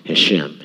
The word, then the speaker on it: Hashem: Most Orthodox Jews use the term, and I could say this wrong, Hashem. Here's how it's spelled. It's H-A-S-H-E-M, and it means the name, the title of the series.